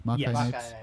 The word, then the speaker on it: Makai Knights: That's how you say it, right? That's their names? – Makai Knights.